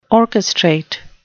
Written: orchestrate

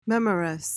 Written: memorous

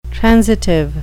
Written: transitive

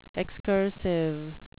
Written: excursive

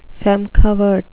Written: feme covert

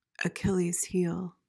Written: achilles heel

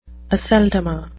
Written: aceldama

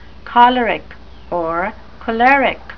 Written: choleric

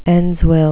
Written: endsville

